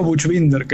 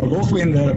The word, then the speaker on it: Buchbinder: Yo digo Buchbinder, que es como la pronunciación medio castellanizada, sí. Pero Buchbinder, Buch es la pronunciación alemana, está increíble.